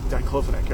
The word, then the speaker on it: diclofenac: It actually had better better uh, outcomes than diclo- diclofenac. diclofenac, right? right? Yeah. I said that right.